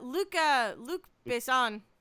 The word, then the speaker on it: Luc Besson: But Luc Besson, right? Is that how you pronounce it? – Yes.